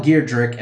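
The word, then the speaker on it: Giedroyc: I always pronounce her last name wrong. – Geirdrick. – Mel Geirdrick and Sue Perkins, later replaced by Noel Fielding and Sandy Toksvig, and the latter was eventually replaced by Matt Lucas.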